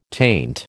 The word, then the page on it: taint: American English